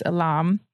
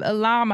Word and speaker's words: Ilham: So there's an artist named, I hope I'm saying it right, Ilam. It's spelled I-L, is it just one L? I-L-H-A-M. So I think it's Ilam.